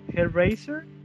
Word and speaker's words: Hellraiser: Hellraiser.